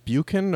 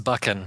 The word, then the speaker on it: Buchan: Was that John Buchan or? – It's pronounced Buchan. – Buchan. – And I know that because I did it wrong, and I had a listener write in and correct me. So, I honestly hadn't heard of that author before, and man, I was completely engrossed in that story.